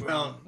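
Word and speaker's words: rhum: Do you pronounce the H in that, though? Do you go rum? – No, it's just rum.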